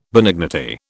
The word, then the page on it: benignity: British English